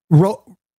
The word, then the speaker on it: Rodon: This is Rondon. – I can't. Oh, so there's a pitcher on the Mets, on the Yankees rather. – Oh, Rondon. – His name is Rodon.